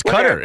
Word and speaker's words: Qatar: Oh, oh no, it's Qatar.